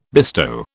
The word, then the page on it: bestow: British English